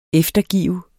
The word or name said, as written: eftergive